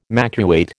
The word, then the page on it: makeweight: American English